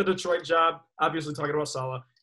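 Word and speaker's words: Saleh: Was it Sala? How do you pronounce the last Robert name? Robert Sala. – It's Robert Sala.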